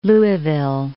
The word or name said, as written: Louisville